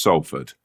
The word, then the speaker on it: Salford: That is Salford.